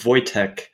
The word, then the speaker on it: Wojciech: Yeah, You were perfect with Wojciech, and most people don't try my last name, but it's Hoczko-Czajko. It's a Polish name.